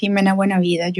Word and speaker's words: Jimena Buena Vida: Um, yeah, so my name is Jimena Buenavida.